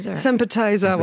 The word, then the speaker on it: Sympathizer: je le prononce bien ? – Sympathizer, voilà. On sent qu'il y a... – Comme l'apéro appetizer.